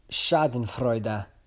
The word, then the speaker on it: Schadenfreude: Hey everybody, today's five words are first, Schadenfreude. Sounds like I'm making a joke. I'm not. It's on the list. It's obviously a German word. It's spelled S-C-H-A-D-E-N-F-R-E-U-D-E.